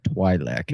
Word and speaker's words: Twi'lek: I would pronounce that Twi'lek.